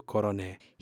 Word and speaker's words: coroné: Coroné is a regional pronunciation of the word coronel or colonel in English. Many